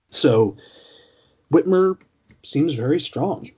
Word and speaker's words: Whitmer: It's Whitmire. – Right. – I'm from the Midwest, trust me.